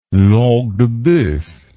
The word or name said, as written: langue de boeuf